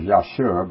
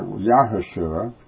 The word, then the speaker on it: Yahushua: I've been pronouncing it with seven letters, Yeshua, but he's supposed to pronounce it that U in there, Yahushua. That would make it eight letters, but meaning eight means a new order.